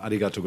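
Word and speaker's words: arigatou (ありがとう): How was the pronunciation there? – Terrible. – Oh, uh, what would be, give us your best. Well, how should it be said? Uh, Nihonjin no minasama, arigatou gozaimasu.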